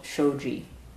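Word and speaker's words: shōji: His final essay, the very last essay in the 95 essays that we call the Shobogenzo, is called shoji, uh, which we translate as birth and death. And actually, this turns out to be the Japanese pronunciation of shengsi, which I mentioned above, that compound that means alive dead.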